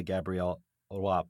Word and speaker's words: Gabrielle Roy: And that brings me to Moving Archives, the book that you won the Gabrielle Roy Prize in.